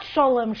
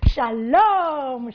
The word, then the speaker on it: shalom: And it's written out in the Yiddish with extra vowels to indicate the pronunciation is not sholem, sholem. It's shalom, shalom.